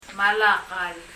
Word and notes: Malakal: maləkəl

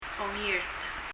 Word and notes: ongirt: [ɔŋirt]

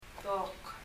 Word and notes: dok: [dɔk]

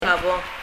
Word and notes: Ka Bong: kʌ bɔŋ

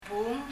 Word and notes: bung: [buŋ]

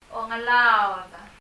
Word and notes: klekool: [klɛkɔ:l]

